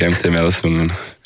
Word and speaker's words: MT-Melsungen: MT-Melsungen, oder wie spricht man es aus? – Genau, das ist richtig, MT-Melsungen.